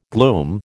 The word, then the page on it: glume: American English